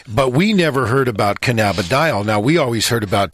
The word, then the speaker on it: cannabidiol: That is cannabidiol.